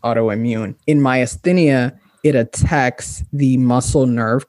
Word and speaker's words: myasthenia: You mentioned that when you were in law school, you were diagnosed with myasthenia gravis. Am I saying that correctly? – Myasthenia.